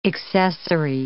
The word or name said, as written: accessary